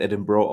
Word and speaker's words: Edinburgh: Edinburgh wird es geschrieben, aber es wird Edinburgh ausgesprochen.